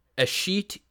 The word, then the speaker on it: Eshete: I believe it's pronounced Eshete.